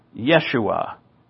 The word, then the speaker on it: Yeshua: Yeshua. That's the Hebrew pronunciation of his name.